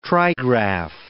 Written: trigraph

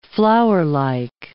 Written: flowerlike

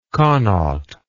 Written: connaught

